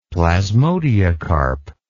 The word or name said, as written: plasmodiocarp